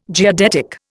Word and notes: geodetic: Indian

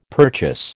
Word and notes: purchase: American English

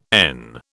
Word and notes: en: British English

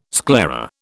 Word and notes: sclera: British English

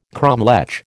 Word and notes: cromlech: American English

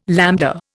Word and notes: lambda: Indian

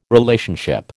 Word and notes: Relationship: American English

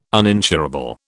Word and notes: uninsurable: British English

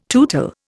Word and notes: tootle: Indian

